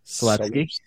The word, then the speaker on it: Selipsky: Adam Selipsky.